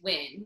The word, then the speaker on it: Wynn: My maiden name is Wynn, which is W-Y-N-N, and people would screw that up too.